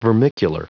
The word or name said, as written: vermicular